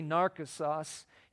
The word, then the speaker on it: Narcissus: It's actually Narcissus is how it's pronounced.